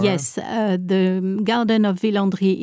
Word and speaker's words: Villandry: And I think Villandry is the, I'm not into gardens that much, but I'll tell you, the gardens at, how do we say it in French? – Villandry. – Villandry.